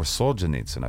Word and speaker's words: Solzhenitsyn: This is Alexander Solzhenitsyn, or Solzhenitsyn, I've heard pronounced it that way, too.